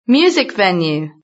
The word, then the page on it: music venue: British English